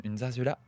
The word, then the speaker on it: zarzuela: Alors, je tiens à dire d'abord que nous avons toutes mal prononcé parce que les deux Z se prononcent et le U se prononce oué. Donc on dirait plutôt zarzuela.